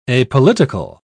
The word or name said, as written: apolitical